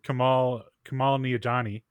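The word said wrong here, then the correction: Kumail Nanjiani: Kumal Nanjiani.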